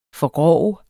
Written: forgrove

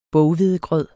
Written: boghvedegrød

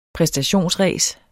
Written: præstationsræs

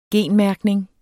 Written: genmærkning

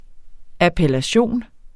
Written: appellation

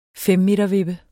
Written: femmetervippe

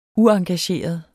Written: uengageret

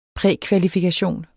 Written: prækvalifikation

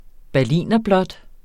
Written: berlinerblåt

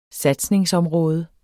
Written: satsningsområde

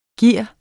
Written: given

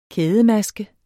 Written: kædemaske

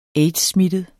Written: aids-smittet

